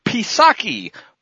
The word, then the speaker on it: Psaki: I follow that up with a headline from the spokeslayer for the regime, Jennifer Psaki. Remember, as in P-resident, the P is silent,